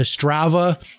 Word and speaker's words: Strava: I, I, I got that the Strava uh, app on my. Is that how you say it? Strava. – Goodness, yeah. – Yeah, I got the Strava yeah, app, Strava, and then yeah.